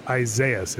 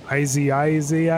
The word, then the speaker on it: Isaias: Yeah, I heard every weather guy did not know how to pronounce Isaias. It's as easy as that. And they were going, Isaias. Yeah, they were adding like extra vowels and S's and Isaias, something like that.